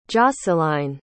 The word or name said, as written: josceline